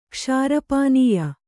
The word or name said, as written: ಕ್ಷಾರಪಾನೀಯ